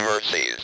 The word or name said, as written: mercies